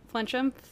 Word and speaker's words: Flinchum: Catherine Flinchem. – I mean, to be fair, nobody in my life, well, in most cases, nobody can pronounce my last name. They're like, Frenchman? And I'm like, it's Flinch.